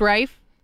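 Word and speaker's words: Raiff: Cooper Rafe, who is 26 Rife. Years old. – It's pronounced Rife. No. I interviewed him last week, and I got him to confirm it himself.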